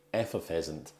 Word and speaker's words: effervescent: And of course, I'm joined by the effervescent, enigmatic, enjoyable Emma. – Enjoyable. Hi. – I think I got stuck on the pronunciation of effervescent. – Yeah, there you go. Okay. That's the correct pronunciation.